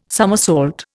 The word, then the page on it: somersault: Indian